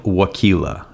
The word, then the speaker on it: Waukila: So Kyle and I both worked at a summer camp in Maine at a place called Camp Waukila. Rhymes with tequila, but it's spelled differently.